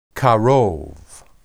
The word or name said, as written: karob